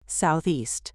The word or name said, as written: Southeast